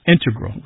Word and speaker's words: integral: Just to clarify here, both correct, but the mathematicians and math-using scientists are more likely to integral, with the stress on the first syllable.